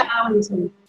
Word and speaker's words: Portarlington: She lives in Portalinkin, I think that's how you say it. Portalinkin, Victoria? – Portalinkin.